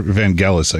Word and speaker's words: Vangelis: And so with Vangelis soundtrack, or Vangelis, I guess is the proper pronunciation.